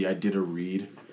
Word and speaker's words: Iditarod: He's saying it wrong. It's the Iditarod.